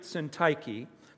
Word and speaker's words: Syntyche: And this is what he says I entreat Euodia and I entreat Syntyche by the way, Jim, good job on pronouncing that.